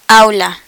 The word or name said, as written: aula